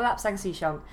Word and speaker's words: Lapsang Souchong: It is worth double checking because that would be, if you know this tea, this would be the most irritating podcast ever if we kept saying it incorrectly. So, yes, we have got Lapsang Souchong.